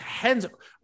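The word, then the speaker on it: Renzo: Is it Chenzo Gracie? Who's Henzo? R E N Z do you say that? – It's Henzo.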